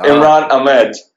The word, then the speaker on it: Imran Ahmed: Imran Ahmed. – All right, you got it right there. Ahmed, right? – Yeah, Imran Ahmed.